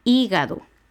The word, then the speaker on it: hígado: Económico, hígado. Son tres sílabas, hígado. La antepenúltima es I, que lleva H al inicio.